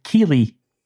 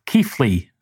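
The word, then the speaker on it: Keighley: And Taylor has very helpfully written out the correct pronunciation of what looks like Keely, because it is in fact called Keefley, the area that Molly Sutton is from.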